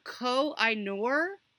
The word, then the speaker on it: Koh-i-Noor: I can't pronounce it. It's the Koh-i-Noor. The Koh-i-Noor. I should know how to say this.